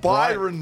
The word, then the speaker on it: Byron Bay: I would say Cairns, dope, but Cairns, Darwin, Townsville, Rockhampton, Sunshine Coast, Bryan Bay, Gold Coast, Gosford, Coffs Harbour. – Stop. You can't just call it Bryan Bay. – Why? – Because it's not Byron Bay.